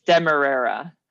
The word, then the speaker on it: demerara: And did we confirm how to say it? – Yes, it's demerara.